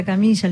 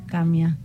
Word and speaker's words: Kamiya: Porque todo el mundo le dice Alejandra Camilla, Alejandra Camilla, y es Alejandra Camia. Se pronuncia así en, japonés.